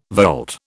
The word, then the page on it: volte: British English